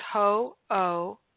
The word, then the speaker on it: Ho'oponopono: Ho'oponopono. Ho'oponopono. Many people pronounce it incorrectly. It's not that hard. It's just Ho'oponopono.